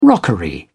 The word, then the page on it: rockery: British English